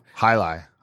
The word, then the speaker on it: Jai-Alai: Hunapu, the Jai-Alai, which I'm probably messing that up. – Hi-Li. Hi-Li. Hi-Li.